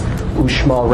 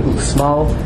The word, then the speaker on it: Uxmal: From there, we went to the colonial city Merida, down for a visit to Uxmal. In the Mayan language, when every time you see an X, they pronounce it sh, so it's Uxmal rather than Uxmal.